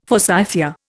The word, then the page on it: forsythia: Indian